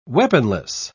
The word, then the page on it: weaponless: British English